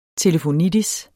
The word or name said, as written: telefonitis